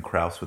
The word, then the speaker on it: Krause: My last name, Kraus, with the word art. – We spell it. – K-R-A-U-S-E.